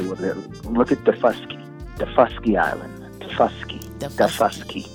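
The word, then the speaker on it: Daufuskie: From the island, that's how I pronounce it. I say I'm Defusky. I'm from Defusky, but we're saying the first key island that we landed.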